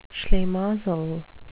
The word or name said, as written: schlimazel